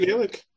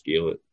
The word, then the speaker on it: Gaelic: I'm gonna get into trouble for saying Gaelic here. – Really? Can't say Gaelic. – Irish is Gaelic, Scots is Gaelic. It's subtle, but they pronounce it Gaelic here rather than Gaelic.